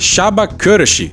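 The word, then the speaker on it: Csaba Kőrösi: Pelo que eu encontrei, essa é a pronúncia correta. Presidente da 77ª Assembleia Geral das Nações Unidas. O negócio se escreve assim, ó. C-S-A-B-A, espaço. E aí vem um nome cheio de acento. K-O com umas aspas em cima cima, R-O com trema S-I.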